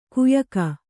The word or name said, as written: ಕಯಿಕೆ